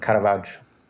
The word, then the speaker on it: Caravaggio: Caravaggio.